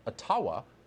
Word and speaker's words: Ottawa: But I do believe it's pronounced Ottawa.